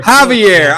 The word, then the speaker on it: Javier: Javier.